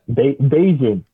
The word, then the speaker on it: Bajan: Bajan.